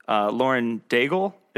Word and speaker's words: Lauren Daigle: It's funny you should say that, because it reminds me of, I think the singer's name is Lauren Daigle, if I'm saying that correctly. – Yeah. – I seem to remember. – That's her name. That's how you pronounce it.